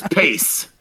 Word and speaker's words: Pace: Sorry. You said passe? – Passe. Is that what it is? – No, I mean, that's probably the correct way of pronouncing it, I would assume. – Yeah, Josiah, you might be too young for this. This is the salsa that when I was a kid, there would be these advertisements about New York City Pace Picante Salsa. It was Pace.